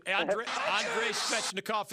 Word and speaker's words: Andrei Svechnikov: I just had his autograph before the season ended, and that's Andrei Svechnikov. I think that's how you say it. – Andrei Svechnikov is correct.